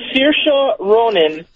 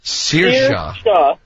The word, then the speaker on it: Saoirse: Sure, it's Saoirse Ronan. – Saoirse.